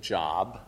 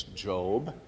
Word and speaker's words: Job: And yes, I know some of you still want to pronounce it Job. It's Job.